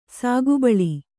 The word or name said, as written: ಸೊಗಬಾಳ್